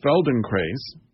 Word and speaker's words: Feldenkrais: But he does Feldenkrais. Is that how you pronounce that? – Yeah, that was pretty good.